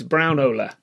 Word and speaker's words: Braunohler: guessing it's Braunohler.